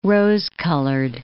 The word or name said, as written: rose-colored